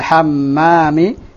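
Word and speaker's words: hammami (الحَمَّام): Fil hammami. – Waman fil hammami. – Nah, karena al-hammam dengan al-hamam nanti beda makna. Al-hamam tanpa mim yang ditasdid itu apa? Al-hamamah, merpati. Ini apa?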